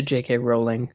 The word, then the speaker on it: J.K. Rowling: Speaking of TV series that I will never be on, Harry Potter, the TV series, is probably not happening, according to J.K. Rowling.